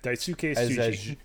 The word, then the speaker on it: Daisuke Tsuji: Daisuke Tsutsu? – Daisuke Tsuji.